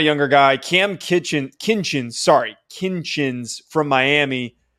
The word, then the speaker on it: Kinchens: I think that's that's obviously the way that you pronounce it. But I always make the mistake, and I think a lot of people think it's Kitchens, but it's not.